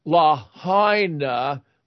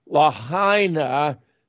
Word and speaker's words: Lahaina: And I've been corrected on the pronunciation. That's Lahaina, Lahaina, the city that was demolished in Maui, I would submit by directed energy weapons.